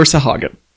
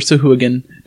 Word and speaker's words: Sahuagin: It was always Sahagin, never Sahuagin, never Sadugin, or however. There's no D in it.